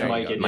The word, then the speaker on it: Mikan: George Mikan. – You're going with an alternate pronunciation, sort of a pecan type deal.